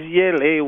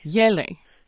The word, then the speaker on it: Yele: Okay, the name is Yele, Y-E-L-E. – Yele.